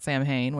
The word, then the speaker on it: Samhain: If you celebrate Samhain, or some people call it Samhain, which is how it's spelled, whatever. It's the witch's new year.